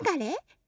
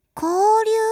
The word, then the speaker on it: 交流: 地方を砂丘や海に囲まれているので、他の町との、流れ？それは交流って読むんです。